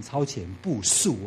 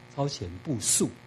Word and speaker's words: 超前部署: 台湾防疫成功是因为真的是超前部署啊，其实要念成超前部署哦，因为那个字是动词哦，知道吗？啊，超前部署，所以从微小的时候就很小心。